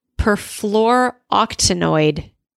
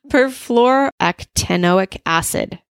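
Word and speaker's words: perfluorooctanoic acid: Okay, next up, perfluoroctanoid. No, I didn't say that right. It sounds like an octopus. Perfluoroctanoic acid, PFOA.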